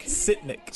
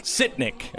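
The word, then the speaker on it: Sytnyk: So their handpicked guy is Artem Stinyak. How do I say it? What's his name? Stinyak? – Sitnik. – Sitnik. Okay, Sitnik. Whatever.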